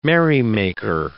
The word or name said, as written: merrymaker